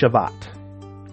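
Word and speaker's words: Shabbat (שבת): So Sabbath in Hebrew, it's pronounced, it's Shabbat, or means to rest.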